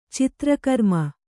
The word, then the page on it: ಚತುರ್ಗ್ರಾಮ: caturgrāma